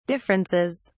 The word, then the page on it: differences: British English